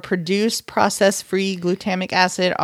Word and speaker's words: glutamic: How about ingredients that may contain or produce processed free glutama- glutamatic Gl- glutamic glutamic acid.